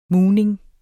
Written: mugning

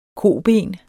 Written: koben

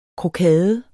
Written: krokade

